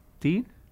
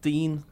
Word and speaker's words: Digne: Just to add to a bit of controversy to the how you pronounce Lucas Digne's name, Sam Carroll, you were in a podcast, the Royal Blue podcast with Phil Kirkbride, through the weekend. He seems to think it is Lucas Dean.